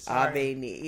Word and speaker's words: Abeni: Abeni, like do re mi. – Abeni. – Abeni.